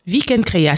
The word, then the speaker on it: Vikendcréa: Vous faites partie de l'association Afrobeats 88 et Vikendcréa, on prononce comme ça ? – Vikendcréa, c'est tout à fait ça.